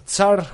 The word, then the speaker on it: tsar: Los Tsar, es que es complicado de pronunciar porque es T-S-A-R. Es jodido, ¿eh? Haced, el, ejercicio de decir tsar, tsar.